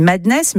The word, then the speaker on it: MDNS: Ultraviolent, avec, je crois, on dit Madness, mais ça s'écrit MDNS.